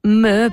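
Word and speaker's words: MØ: Eh sì, si pronuncia proprio così, Me, perché è scandinava, adesso non mi ricordo più se è svedese o vabbè, comunque in quella zona d'Europa.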